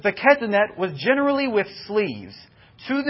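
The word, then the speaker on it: Ketonet (כתונת): Ketonet is the way that this word is pronounced.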